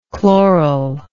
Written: chloralize

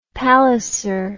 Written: palliser

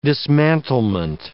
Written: dismantlement

